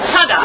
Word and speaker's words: Toda: They say it kind of like Toda. – No, yep. – Toda. – One second syllable. Toda. Toda.